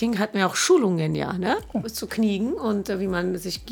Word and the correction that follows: Knigge: Sag mal Kniegen, ich bin mega verwirrt. Knige. Knige, okay, ja. so spricht man das aus.